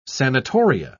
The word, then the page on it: sanatoria: British English